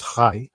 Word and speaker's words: chi: The term chiasm comes from the Greek letter chi, or if spoken in Greek, it's actually pronounced chi, and which looks like our letter X.